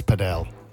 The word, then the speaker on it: padel: Well, if you like squash a little bit and you also like tennis, then paddle. – Yeah, but sorry, can I just stop you? It's padel.